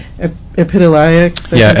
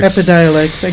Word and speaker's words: Epidiolex: So I wanted to ask you also, so recently the Big Pharma has come out with their own CBD product, Epidiolex. – Epidiolex. – I keep saying it wrong. Epidiolex.